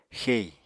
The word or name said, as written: xei